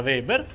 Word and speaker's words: Weber: che mi pare vi avevo menzionato l'altra volta dicendomi che si pronuncia Weber, perfetto, che dice una cosa abbastanza impegnativa.